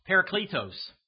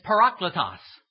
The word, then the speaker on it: parakletos: I used to call it parakletos. It's parakletos. It's all in how you accent the syllables. Parakletos, okay? P-A-R-A-K-L-E-T-O-S.